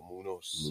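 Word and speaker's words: Muñoz: Munoz. – Yeah. – It's not Munoz, but Munoz.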